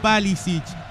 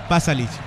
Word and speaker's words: Pašalić: En el lado croata ingresó Mario Pašalić. Pašalić, perdón. Sí. La pronunciación es muy complicada con tanto ich en estos croatas.